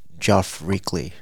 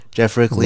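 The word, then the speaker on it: Geoff Rickly: We actually reached out to Geoff Reekley. I didn't say it right. Geoff Reekley. – Uh-huh. – Geoff Reekley. Okay, there we go. Geoff Reekley. – Nailed it.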